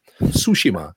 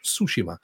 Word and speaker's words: Tsushima: Tsushima, actually, is how it's pronounced. Tsushima.